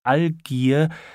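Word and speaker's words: Algier: Er sagte kurz ihre Lebensweise und bemerkte noch dabei, dass in dem Worte Allgier das G auch wie ein G ausgesprochen werden müsste.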